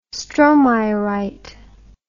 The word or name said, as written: stromeyerite